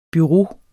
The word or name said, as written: bureau